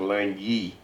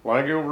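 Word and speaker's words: L'Anguille: The thing that is hard to grasp, Langill, it's actually pronounced Langy. It's French for water snake.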